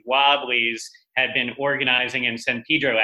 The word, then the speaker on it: San Pedro: It's spelled San Pedro, but the local pronunciation is San Pedro or Pedro.